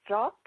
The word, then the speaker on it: Strat: So it's easy to find me on Facebook or on Skype, and my name is Maria, and it's spelled M-A-R-I-J-A, and my last name is Strat, S-T-R-A-T.